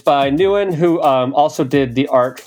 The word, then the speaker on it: Nguyen: So you would pronounce it as if you spelled it N-E-W-I-N. Nguyen. – Okay, Nguyen. – Nguyen.